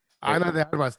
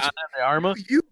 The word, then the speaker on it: Ana de Armas: I want to see the, what is it, the Arma? – Ana de Armas. – Yeah, bitch, Ana de Armas.